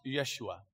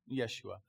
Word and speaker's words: Yeshua: It's back then it was probably pronounced Yeshua. Yeshua.